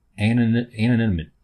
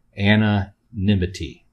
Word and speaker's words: anonymity: I guess it's a fresh take on anonymity. I'm saying it wrong. Anonymity.